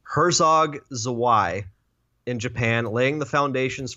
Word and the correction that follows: Herzog Zwei: Yeah, get that Herzog Zwei.